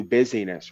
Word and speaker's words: busyness: our kids off to these mass factories to be looked after and raised and taught so that mommy and daddy can pursue busyness, right? The word is business, but it's spelled busyness.